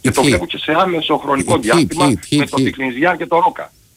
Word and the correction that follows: Τικνιζάν: Ο Τικνιζιάν με το Τικνιζάν, γιατί δεν προφέρεται, λέει, είναι μένει με το Α. Τικνιζάν, λοιπόν, να το λέμε.